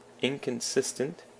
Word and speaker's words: inconsistent: And then inconsistent has the emphasis on the sis as well, inconsistent, and it's an adjective that means 不一致的.